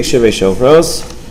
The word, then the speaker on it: shivrei shofros: Dibek shivrei shofros. You said it correctly. Dibek shivrei shofros.